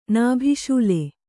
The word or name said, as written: ನಾಭಿಶೂಲೆ